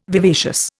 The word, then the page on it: vivacious: Indian